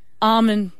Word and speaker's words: almond: So you're saying the L is silent? – I am. And I am terrible with English. I'll first put my hand up and say I am so bad with English, but I'm nearly adamant that it is almond. – Well, why don't they spell it A-H-M-O-N-D?